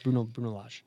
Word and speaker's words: Bruno Lage: That's not even the correct pronunciation, but it's Bruno Lage.